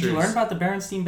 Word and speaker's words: Berenstain: It's Bernstein. – Actually, it's Berenstain. – What the fuck? – S-T-A-I-N. Yeah.